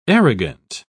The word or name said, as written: arrogant